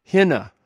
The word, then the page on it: hina: greek